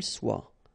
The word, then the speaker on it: soit: qu'elle soit. Okay, so as usual, good news is that soit, soit, soit, and then soit here. You write them differently, but then you pronounce them the same way.